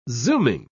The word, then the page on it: zooming: British English